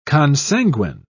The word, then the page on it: consanguine: British English